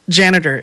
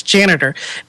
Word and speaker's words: Janetter: What I use for Twitter is Janitor, and it's spelled Janetter. That's Janitor. And the cool thing about it is it's completely customizable.